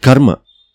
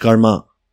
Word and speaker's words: karma: To take a little bit abstract example of the word karma, pronounced karma in English, karm in Hindi, and karma in Assamese.